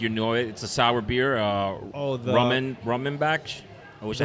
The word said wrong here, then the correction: Rodenbach: I had Rodenbach Grand Cru.